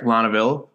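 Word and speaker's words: Laneuville: Spell it and let me try to say it. I'm going to type it out while you spell it. Hold on. L. – A. – A. – N. – N. – E. U. And then Ville. – Lonaville. That's what I was going to say. I nailed it.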